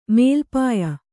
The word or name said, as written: ಮೇಲ್ಪಾಯ